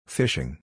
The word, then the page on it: Phishing: English